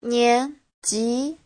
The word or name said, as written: nian2ji2